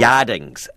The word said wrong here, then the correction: yardang: which is... – Where is it? Yardang. – Yardangs.